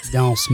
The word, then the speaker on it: danse: how do you say this cat? Dance, danse. – Danse. – Danse macabre.